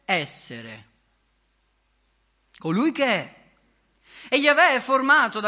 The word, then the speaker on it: Yahweh: E così, mettendo insieme Y, H, W, H e le vocali di Adonai, è venuto fuori Yahweh. E si è giunti così alla pronuncia Yahweh.